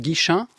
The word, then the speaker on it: Guichen: Vous êtes coprésidente du cinéma Le Bretagne à Guichen, c'est bien comme ça qu'on prononce, Guichen, en Ille-et-Vilaine. – Oui, c'est bien ça.